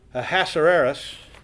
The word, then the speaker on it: Ahasuerus: Now, the person in our study today is Xerxes, which is also Erasmus. I said that wrong. I have to look at it when I say it. Ahasuerus.